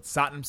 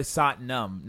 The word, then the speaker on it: Satnam: The next player is a player we know all too well in this podcast, and apparently we've been pronouncing his name wrong, but Satnam Singh. His name is apparently Satnam, not Nam. So the Satnam shame that we do is not necessarily a rhyme because the A sound and the U sound.